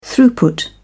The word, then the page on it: throughput: British English